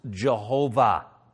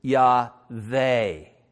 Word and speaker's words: Yahweh: the pointing and the way you pronounce it is Yahweh. Now, some people say Yahweh, but that's just, you know, that's English. The vav in Hebrew is just that sound, vav, Yahweh. Now, Yahweh is God's name, and it means something like I am that I am.